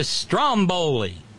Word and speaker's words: Stromboli: The stress is on the first syllable. It's a volcano on an eponymous island off the coast of Italy. Stromboli. Like Strom Thurmond, right? Well, you know, we're accustomed to with Latin languages to putting the emphasis on the next to last syllable.